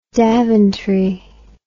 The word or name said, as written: daventry